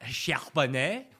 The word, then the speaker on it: Charbonnet: Oh, Zach Charbonnet.